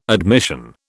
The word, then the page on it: admission: British English